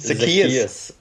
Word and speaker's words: Zacchaeus: Zake, Wie spricht man diesen Kerl aus? – Zacchaeus.